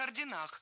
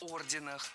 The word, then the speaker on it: орденах: Рыцарские ордена, о рыцарских орденах.